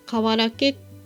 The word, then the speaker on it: 土器: 大山では厄除け開運になるという瓦け投げというのがあるんですが、瓦けというのは漢字で土器と書いて瓦けと読むんですが、私は今年後役なのでぜひやろうと思い大山寺に寄りました。